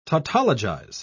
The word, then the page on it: tautologize: British English